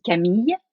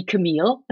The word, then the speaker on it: Camille: No, it's very good. So my name is Camille, and you can call me Camille. There's no problem. So Camille or Camille, and Chevalier-Karfis, if you want to be more French.